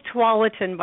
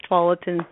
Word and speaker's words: Tualatin: And it's pronounced Tualatin, by the way. A lot of people see that spelled out. And they're like, how do you say that? It's Tualatin. Yeah, so Tualatin Chamber.